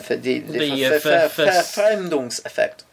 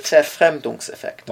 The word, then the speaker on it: Verfremdungseffekt: Verfremdungseffekt. – Is that how you pronounce it? – Verfremdungseffekt.